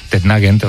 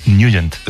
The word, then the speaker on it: Nugent: Hombre, siempre lo hemos llamado en el barrio Tetnagent o Tetnagent. – Claro, yo era de Tetnagent. – Pero la pronunciación correcta es Nugent.